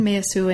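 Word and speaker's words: Meillassoux: and that is Quentin Meyasu. And forgive mm-hmm. my pronunciation of everything. Is that that's right? That's correct. – So you introduce very early in the book Quentin Meyasu and his idea of correlationism, or his engagement with the idea of correlationism, and this motivates much of what comes after.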